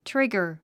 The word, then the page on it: trigger: tríɡər; トゥリガァ